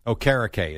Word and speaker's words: Okereke: Well, I went and watched 3 or 4 videos from CBS and Fox from this season. – Okay. – Okereke is how all Okereke. – Okereke. – Okereke. – Yes, that's how all the announcers call them.